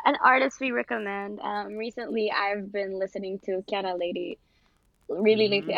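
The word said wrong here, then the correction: Kiana Lédé: I feel like I'm not pronouncing her name right. Kiana Lede.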